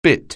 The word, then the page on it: bite: anglais